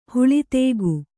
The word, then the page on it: ಹುಳಿದೇಗು: Kannada